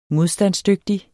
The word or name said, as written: modstandsdygtig